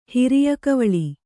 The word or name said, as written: ಹಿರಿಯಕವಳಿ